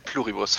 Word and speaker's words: Pluribus: Ich mach's lateinisch und mach einfach einen auf die Buchstaben hintereinander weg. Pluribus, fertig.